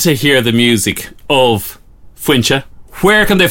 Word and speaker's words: Foinse: If you want to find us as well, Fuincha's, and I'm doing F-O-I-N-S-E is how you spell it, and it's pronounced Fuincha.